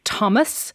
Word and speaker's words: Thomas: Please stop pronouncing the H in Thomas, Thailand and the Thames River.